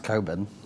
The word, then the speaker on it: Cockburn: Cockburn got his way, but not until 1880. – It's pronounced Coburn.